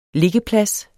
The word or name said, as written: liggeplads